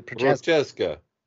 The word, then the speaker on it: Prochazka: Skipping ahead, Yuri, I can't say his name. Prochazka. Yeah. – Prochazka. Yes.